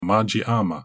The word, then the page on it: Majii-Ama: Galindri